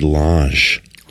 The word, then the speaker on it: Lage: How do you pronounce it? Lage? Lage? – I always said Lage. – Lage. – Yeah, but I don't know. It's spelled L-A-G-E.